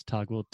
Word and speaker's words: Toggl: It's called Toggl. I think that's how you pronounce it. Toggl? – Yes, that's Toggl, T O G G L.